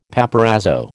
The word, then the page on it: paparazzo: American English